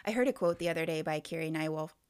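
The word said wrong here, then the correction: Nieuwhof: Newhoff. Sorry, I always say his name wrong. If you saw how it's spelled, you would say it wrong too.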